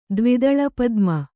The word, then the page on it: ದ್ವಿದಳಪದ್ಮ: dvidaḷa padma